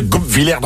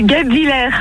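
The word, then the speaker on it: Guebwiller: On y est presque, la prononciation est pas ouf. – Oh là là là là là là là. Vous avez raison. Je sais pas parler. Oh là, comment qu'on prononce? – C'est Goupvillère.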